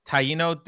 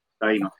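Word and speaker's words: Taíno: And I mentioned earlier that you were of Taino descent. Is that how I pronounce? Did I pronounce it wrong? Or yes, that's right. Taino.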